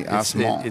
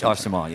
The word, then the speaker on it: Arcement: It's spelled A-R-C-E-M-E-N-T. Arsman. – It's Arsman, yeah.